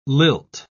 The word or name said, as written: lilt